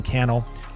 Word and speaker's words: Cannell: My name's Sean Cannell, rhymes with YouTube channel, and I look forward to seeing you in a future episode of the Think Media Podcast.